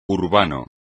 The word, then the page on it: urbano: espagnol